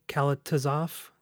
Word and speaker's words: Kalatozov: Um, and it's uh, it's directed by Mikhail uh, what Kalatozov.